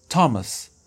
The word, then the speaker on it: Thomas: Y por último, vamos a ver una excepción que también se puede representar con la TH cuando nos referimos a algunos nombres, como uno muy, muy común es Thomas. Thomas, que es Thomas, en inglés se escribe TH.